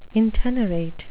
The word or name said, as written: intenerate